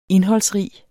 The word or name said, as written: indholdsrig